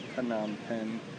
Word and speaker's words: Phnom Penh: Yeah, but then, so our next stop, we were heading to Phnom Penh. – Phnom Penh. – Phnom Penh. – It looks like Phnom Penh, but I guess it's pronounced Phnom Penh.